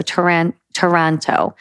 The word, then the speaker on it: Taranto: I'm going to go with Taranto, not as in Toronto in, you know, Canada, but as in Tarantula. So Taranto.